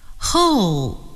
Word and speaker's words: whole: Whole. The whole world. 今天我们一起看了字母组合 WH 在一起的时候的两种读音。你还记得他们是什么吗？对了，他们是呜和呵。